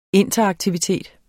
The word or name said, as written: interaktivitet